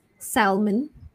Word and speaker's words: salmon: Next, S-A-L-M-O-N, silent L. So you might, previously you might have thought it's salmon. So it's actually salmon. Salmon. So silent L there. Salmon. And the day of the week, W-E-D-N-E-S-D-A-Y.